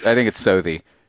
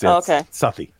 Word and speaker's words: Southie: I think it's Southie. – Yeah, it's Southie.